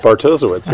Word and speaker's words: Bartosiewicz: Bartosiewicz, yeah.